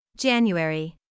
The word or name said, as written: January